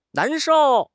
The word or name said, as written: nan2 shou4